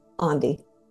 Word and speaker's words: Andy: So I joke, I tell people, if it's helpful, it rhymes with Gandhi. So if you're ever like, how do I say her name? It's like rhymes with Gandhi, Andy.